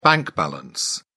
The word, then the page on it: bank balance: British English